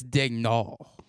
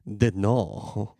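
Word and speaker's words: Daigneault: It's Dagnault. – Dagnault.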